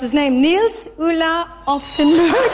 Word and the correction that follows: Oftebro: And the winner was Nils Ulla Oftebro.